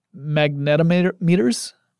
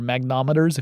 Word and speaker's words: magnetometers: They also had experiments that had magnetometers, or magnometers if you prefer, but that I'm almost certain that's not the right way to say it.